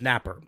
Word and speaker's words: Napier: The other reason that we are a day late is many of you knew it's spelled Napier but pronounced Napper.